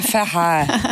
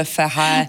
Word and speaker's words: Ferret: Alors, comment prononcez-vous Cap Ferret ? – Le Ferret. Voilà, ça va faire plaisir à notre auditoire, le Ferret.